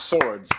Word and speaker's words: Sowards: Yeah, it's Swords, like two swords. Mm-hmm. Multiple, plural.